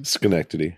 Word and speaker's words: Schenectady: Schenectady.